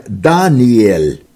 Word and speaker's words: Даниэль: Имя Даниил, которое правильно читается как Даниэль, означает «мой судья Бог», потому что слог «эль» означает на еврейском языке «бог».